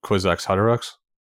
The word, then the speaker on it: Kwisatz Haderach: that he has to die to become the Kwisatz Haderach.